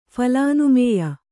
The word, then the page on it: ಫಲಾನುಮೇಯ: phalānumēya